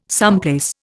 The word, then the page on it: someplace: Indian